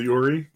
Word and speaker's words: Uri: You know, for example, you'll have, you know, like if I bring up, I'm currently looking up Yuri, or I forget how to pronounce her name. – Which one? – URI. – Oh, the Yuri? – Yeah.